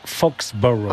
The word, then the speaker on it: Foxborough: On va retourner à Foxborough parce que ça y est. – Vous le dites vachement bien. – Ouais, Foxborough.